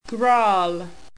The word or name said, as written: grarrl